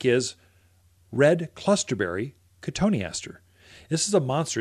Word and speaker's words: cotoneaster: One I like, especially if you're in the forested areas where you've got a lot of deer, even elk, you'll look at cotoneaster or cotton easter is how you spell it.